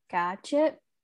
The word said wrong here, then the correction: Gachet: How would you say that in French? – Dr. Gachet.